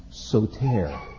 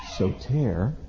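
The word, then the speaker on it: soter (σωτήρ): And this would be pronounced soter, soter, and it means Savior.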